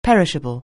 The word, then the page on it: perishable: anglais